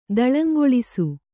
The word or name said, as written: ದಳಂಗೊಳಿಸು